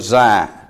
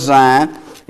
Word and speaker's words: Zion: He's going to be set upon God's holy hill of Zion. And in Hebrews chapter 12, beginning in verse 22, but ye, Christians, are come to Mount Zion, spelt Z in the Old Testament and spelt with an S in the New Testament.